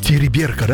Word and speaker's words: Териберка: Териберка, да? Мне уже ударение на второй слог, да? Териберка.